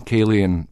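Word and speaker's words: Ceilidh: I mean, Cayleyan is a Gaelic word, which is about 20,000 letters that you don't pronounce. It's just Cayley.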